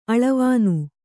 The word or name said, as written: ಆಲವಣ್